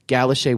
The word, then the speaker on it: Galocher: Now that is spelled G-A-L-O-C-H-E-R. – French. – But she put it phonetically for me. Galoche.